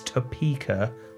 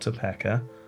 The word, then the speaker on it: Topeka: It's Topeka, not Topeka. Okay. Topeka.